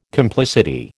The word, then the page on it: complicity: American English